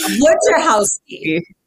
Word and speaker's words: Wojciechowski: Wojciechowski.